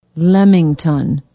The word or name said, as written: Lemington